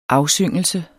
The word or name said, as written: afsyngelse